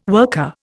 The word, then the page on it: worker: Indian